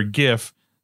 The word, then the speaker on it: GIF: The winner is hard G for Gif, soft G for Giles, and that was just throwing me off because did you not see the CNN? – Yeah, fake news.